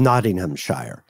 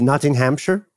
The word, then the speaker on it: Nottinghamshire: Well, I think the shire, you could say it as just Nottingham or Nottinghamshire. And I only say sheer because that's how they say it in A Day in the Life, the Beatles song.